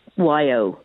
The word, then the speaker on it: ewe: and I have to explain to them that it's spelled E-W-E and it's pronounced Y-O, like yo, as in hi, yo.